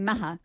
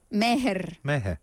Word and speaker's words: Meher: I mind you calling him Maha. – Meher. – You have to pronounce the R, otherwise it sounds like Maha.